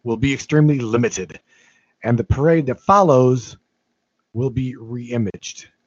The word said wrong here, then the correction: reimagined: Reimagined, not reimaged. There's another syllable in there.